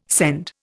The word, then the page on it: scend: Indian